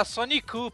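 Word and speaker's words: Sonicu (ソニック): Não, ele fala Sonic não, ele fala Sonicu, porque aí tá em japonês.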